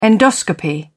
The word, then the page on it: endoscopy: British English